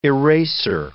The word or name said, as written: eraser